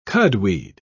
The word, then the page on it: cudweed: British English